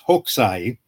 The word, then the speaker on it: Hokusai: In a way, first of all, we should explain to the people who are viewing, the person you're talking about, his name is pronounced Hokusai.